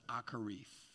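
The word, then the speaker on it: akarith (אחרית): Now, it's spelled really weird because Hebrew is nuts, but it's pronounced akarith.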